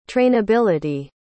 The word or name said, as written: trainability